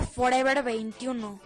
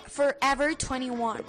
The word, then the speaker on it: Forever 21: No se dice Forever 21, se dice Forever 21.